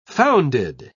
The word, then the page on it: founded: British English